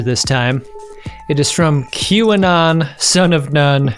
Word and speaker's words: QAnon: uh, they uh, I've got to I've got to express some gratitude for the uh, pronunciation key here, uh, advising me that the pronunciation of QAnon is in fact QAnon.